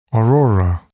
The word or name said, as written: aurora